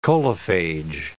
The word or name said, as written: coliphage